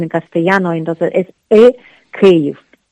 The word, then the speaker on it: Kyiv: Entonces, dime cómo tengo que decir Kiev adecuadamente, porque al parecer, si digo Kiev, estoy siendo prorruso. – Porque pronunciar Kiev es pronunciar en ruso. En ucraniano lo correcto sería Kyiv. – Kyiv.